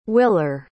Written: willer

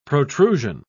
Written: protrusion

